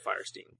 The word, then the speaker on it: Fierstein: Fierstein? I'm never sure how to say his name. – I've always heard of Fierstein. – Fierstein? Okay. It sounds better anyway.